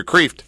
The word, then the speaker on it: Kreeft: Well, that's the American way of doing it. The Dutch is Kreeft, like crayfish. It means lobster.